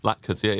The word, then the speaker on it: latkes: Bubala's confit potato latkes. Latkes. How would you say that word? – Yeah, latkes. Yeah.